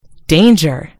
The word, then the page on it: danger: American English